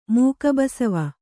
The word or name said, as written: ಮೂಗುಬಸವ